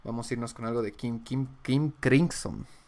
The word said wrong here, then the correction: King Crimson: King Crimson.